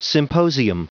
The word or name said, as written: symposium